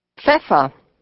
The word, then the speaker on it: Pfeffer: The p sound can also be a little difficult. Just think of Humphrey Bogart. – Pfeffer.